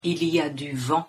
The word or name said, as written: il y a du vent